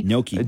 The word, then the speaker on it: gnocchi: Gnocchi. – G-N-H-O-C-C-H-I.